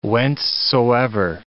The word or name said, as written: whencesoever